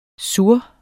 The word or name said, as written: sur